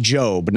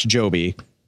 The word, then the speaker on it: Jobe: It's Josh Jobe, not Josh Joby.